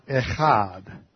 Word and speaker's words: echad: Now this word one is the Hebrew word echad. Echad. You can spell that in English, E-C-H-A-D.